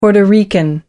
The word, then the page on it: puerto rican: American English